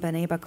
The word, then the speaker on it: Beneba: Can you talk by Maxine Beneba Clark? Am I saying that correctly? – Yeah, I think it's Maxine Beneba Clark. Beneba.